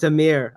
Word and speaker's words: Amir: Amar, say your name for me, because I'm probably saying it wrong. – You're good, Joe. It's Amir. Amir Agoora.